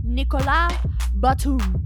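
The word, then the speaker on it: Nicolas Batum: Up next, I've got, and I'm going to pronounce it the way that he pronounces it, Nikola Batum.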